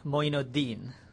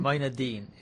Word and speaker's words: Mojadin: I go by Moin Mojadin. So that's Moin Mojadin? – Mojadin. – Exactly. Oh, okay. So it kind of sounds like the first part repeated again. – Repeated again. yeah, that's right.